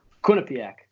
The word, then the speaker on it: Quinnipiac: Quinnipiac. – Quinnipiac. – Quinnipiac. You can't pronounce the second I. – Okay. – Like Quinnipiac. You got it all the way through.